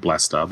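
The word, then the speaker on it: Blastaar: And do you actually pronounce it as Blastar? – Yeah, that's what I would say. – We're going to go with Blastar.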